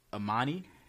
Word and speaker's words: Amoney: Oh, I think her name is Amani. It was A-M-O-N-E-Y. So it's spelled like A Money. – Mm-hmm. – But I think it was Amani.